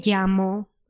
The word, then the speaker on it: llamo: The double L in Spanish is similar than in English Y. So then, for example, when you say your name and you say me llamo, and after your name, the word llamo has a double L, but we don't pronounce it as an L. We pronounce it similar than a Y. Llamo. Llamo.